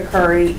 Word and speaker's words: Curry: Dr. Erica Curry, E-R-I-C-A-C-U-R-R-Y.